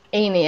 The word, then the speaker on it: Haney: The H is silent. – Haney. – Haney, yeah.